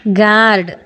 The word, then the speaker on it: GUARD: GUARD ಗಾರ್ಡ್, BALM ಬಾಮ್, PALM ಪಾಮ್, ಈ ಥರದ ಒಂದಷ್ಟು ಪದ ಹೇಳಿದೆ. ಇಲ್ಲಿ ನೋಡಿ, GUARD ಗಾರ್ಡ್ ಆರ್ ಉಚ್ಚಾರ ಆಗಲಿಲ್ಲ, PALM ಅಲ್ಲಿ ಎಲ್ ಸೈಲೆಂಟ್ ಆಗ್ಬಿಡ್ತು.